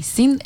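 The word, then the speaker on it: Cin: that was Amy Sin. – Yep. – With Delta. – Yeah, and definitely check her out on her website, Amy Sin. It's pronounced Amy like Amy, and Sin is with a C-I-N.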